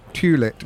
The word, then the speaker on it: Tulett: Well, T-U-L-E-T-T, Tulit. – No, it is correct. You're right. We had this out, didn't we? We really had this out this afternoon that it is how you would pronounce it if you read, you know, if you respect conventional English pronunciation. But Tulit, it's Tulit, not a more common English surname.